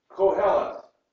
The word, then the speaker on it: Kohelet: Kohelet starts with a Q sound in the Hebrew. We would pronounce it as a K sound in our English. Kohelet.